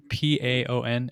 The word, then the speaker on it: Paon: Shout out to I don't know how to pronounce it. Bayon. – It's a Minestrone soup. This is Paon. It's French for, I looked it up afterward, French for peacock.